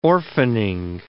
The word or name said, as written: orphaning